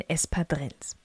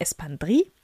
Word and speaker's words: Espadrilles: Und das machen wir am besten mit den Espadrilles. Das ist die spanische Aussprache. Diese Schuhe kommen nämlich sowohl aus Spanien als auch aus Frankreich. Wenn du die französische Aussprache haben möchtest, dann natürlich Espandrille.